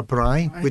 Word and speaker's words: braai: But is it a braai? – A braai, which I got that right.